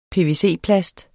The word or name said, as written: pvc-plast